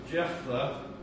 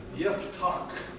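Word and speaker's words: Yiftach (יפתח): And that's a man by the name, like I said last week, I like to pronounce it in the Hebrew. Probably in English we would say Jephthah, but actually the Hebrew, and I'll talk about that when we open this up, is really Yiftach, and so it has more of a guttural sound.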